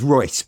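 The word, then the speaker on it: Reuss: I mean, I love the House of Raus. It's Royce, but yes. – Yeah, yeah. I mean, I always go with Raus because I can never not pronounce it like it's Disney.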